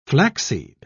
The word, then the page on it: flaxseed: British English